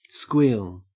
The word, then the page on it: squeal: Australian English